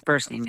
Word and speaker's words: Burstyn: It has a Blake Lively, Michael Huseman, Harrison Ford, Kathy Baker, Ellen Burstyn. Right? Am I saying that right? – Yeah. – Yes, Burstyn, yeah.